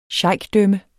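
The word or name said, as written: SHEIKDØMME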